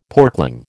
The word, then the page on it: porkling: American English